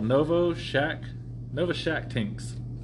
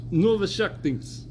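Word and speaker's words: Novoshakhtinsk: So, it was called Novoshanktits. – Not Shanktits. – It's actually called Novoshaktinks. – Novoshaktinks.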